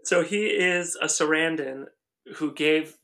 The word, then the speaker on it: Sarandon: It's Sarandon, the first husband of Susan Sarandon.